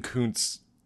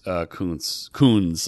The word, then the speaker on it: Koons: So you're probably like, oh, you're going to see a Jeff Koons exhibit. You also gave Jeff Koons' name a real Dean Koontz pronunciation there.